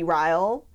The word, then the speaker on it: Ryle: And also, how do you think that his name is pronounced? Ryle? – I do not say Ryle. – What do you say? – I say Riley, even though, phonetically, I think at one point she says her sister names their kid Riley, so he must be Ryle. – Oh, yes, probably. – But that's a terrible name, and I'm not saying it. It rhymes with Kyle, so I was like, I guess.